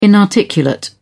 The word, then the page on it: inarticulate: British English